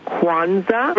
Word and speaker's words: Kwanzaa: I would like to, first of all, tell your guest that it is pronounced Kwanzaa. – Mm-hmm. – It is spelled Kwanzaa.